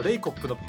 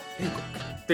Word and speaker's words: レイコップ: あのハンドクリーナーのレイコップのプの無声化がすごい上手だなって思ってる。 – 何言ってるのか分かんないね。 – ナレーションとして誰か分かんないんだけど、レイコップっていうね、プの発音のね、無声化がすごい上手なんだよ。